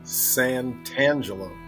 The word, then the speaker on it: Santangelo: Santangelo.